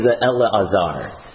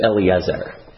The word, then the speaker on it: Elazar: Well, because it's pronounced as Elazar, as opposed to Eliezer.